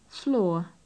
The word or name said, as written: floor